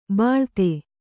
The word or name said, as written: ಬಿಳ್ತು